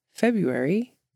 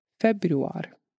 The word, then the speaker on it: februar: The Icelandic word of the episode is how you say the word February in Icelandic, which is februar.